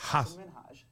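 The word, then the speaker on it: Hasan Minhaj: Yeah, but the real way you pronounce it, and this is a big deal because my parents are here. It's Hassan Minhaj. – Oh, sorry. So I talked over him. – Good, please. – So is the emphasis is on ha.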